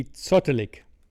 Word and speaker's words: zottelig: German word would be zottelig. Zottelig. – Spell it. – Z-O-T-T-E-L-I-G.